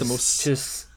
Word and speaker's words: Lanthimos: Yorgos Lanthimos, I can never pronounce his name. But it is Lanthimos. Lanthimos, thank you.